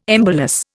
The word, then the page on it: embolus: Indian